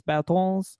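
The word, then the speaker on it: Bertāns: But I do believe it's pronounced Davis Bertans.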